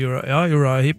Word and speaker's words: Uriah Heep: Har du hørt om en gruppe som heter Uriah Heep? Uttaler jeg det riktig? – Ja, det vel Uriah Heep, ja.